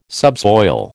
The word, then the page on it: subsoil: American English